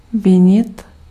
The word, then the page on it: reprocher: [ʁə.pʁɔ.ʃe]